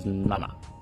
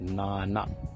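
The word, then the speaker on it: nana (なな): So 7 in Japanese is nana. You pronounce that? It's nana, nana. So we'll go through the Japanese numbers quickly,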